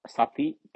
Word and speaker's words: sati: Upekkha. UPEKKHA long A upekkha dash SATI sati dash, because this is one word, supposed to be one word, but so I separate PA long A RI SUDDHI parisuddhi.